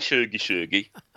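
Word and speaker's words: tjugo tjugo: I like shuggy, shuggy. That's Swedish for 2020, by the way, everybody. – It is, yes, yes. And the pronunciation is, you know, bang on. I'm so impressed.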